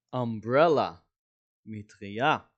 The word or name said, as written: umbrella